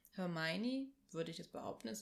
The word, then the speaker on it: Hermione: es versucht, ihm beizubringen und dann wirklich Hermione.